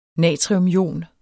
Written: natriumion

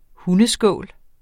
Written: hundeskål

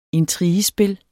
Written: intrigespil

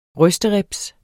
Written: rysteribs

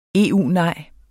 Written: EU-nej

